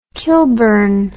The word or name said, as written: kilburn